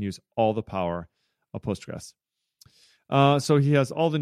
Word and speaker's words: Postgres: How do you say it? – Postgres. Yeah, just Postgres.